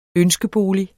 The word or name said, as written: ønskebolig